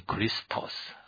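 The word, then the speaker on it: Christos (Χριστός): 那么其实希腊文它念成 Christos，我们翻成基督是一个把这个 Christos 把它简化简称为基督，那这是希腊文叫 Christos 或者说基督。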